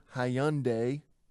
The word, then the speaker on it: Hyundai: I work down at the pizza pit, and I drive an old Hyundai. Is that how you say it? – Yes. Absolutely. You're doing well.